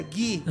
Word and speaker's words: Guy: En francés es un nombre que se pronuncia Guy.